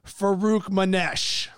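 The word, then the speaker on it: Farokhmanesh: Number 9, Northern Iowa, led by Ali Farouk Manesh. – You nailed it. Confidence. I love it. – Yeah, it helped me being able to see Farouk Manesh to be able to pronounce that.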